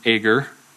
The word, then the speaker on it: Agur: And tonight we have a prayer from a man named Agur. That's how I pronounce it, A-G-U-R, Agur, from Proverbs 30.